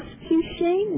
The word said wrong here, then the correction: Escúchame: Oh, Escúchame.